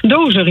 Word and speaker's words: Dozer: Dozer, zo moet ik het uitspreken. – Dozer, ja, dat klopt.